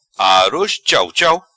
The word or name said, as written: Aroz Chauchau